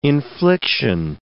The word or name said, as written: infliction